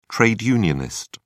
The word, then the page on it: trade unionist: British English